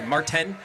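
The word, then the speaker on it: Märzen: This is the Marten, which I just learned today. You pronounce that Z like a T.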